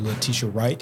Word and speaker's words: Letitia Wright: So speaking of shutting down, the production of Black Panther 2 Wakanda Forever has been put on hold while Letitia Wright. Did I say that right? – Yep.